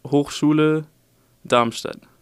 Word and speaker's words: Hochschule Darmstadt: So you're a student in, let me see if I can pronounce this, if I can get it close, Hochschule Darmstadt. – It's, if you pronounce it in German, it would Hochschule Darmstadt. – Oh, wow. Okay. – Hochschule Darmstadt.